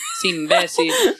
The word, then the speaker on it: imbécil: Y yo, sí, de verdad, es un imbécil, con acento en la B. Y yo, todo el mundo así como que, ¿what? ¿Que, ¿cómo es acento en la B? Ah, disculpa, en la E.